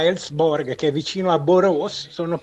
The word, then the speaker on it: Borås: Poi avete sentito, la pronuncia è quella giusta, Borås, perché con le lettere con il tondino sopra nelle lingue scandinave e anche norvegese diventano praticamente una O.